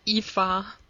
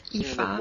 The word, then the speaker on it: Aoife: I believe it's Eva. – It's Eva. Wacky Irish. – This one I'm sure about. Of any of the pronunciations. This one I'm sure of. – Sounds like Eva and Wally.